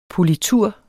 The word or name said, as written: POLITUREN